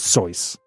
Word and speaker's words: Seuss: If you're calling him Seuss, he pronounces it Seuss." – Rejuice. – Yeah, because in German, the S's come out as Z's.